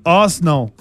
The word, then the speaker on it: Arsenal: ¿Ustedes sabían que en Inglaterra le dicen en dos sílabas? – ¿Ar? – Arsenal.